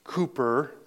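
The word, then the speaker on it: Cowper: Written by William Cooper, is how you pronounce that name. You have to be English.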